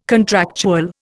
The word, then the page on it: contractual: Indian